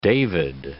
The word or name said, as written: David I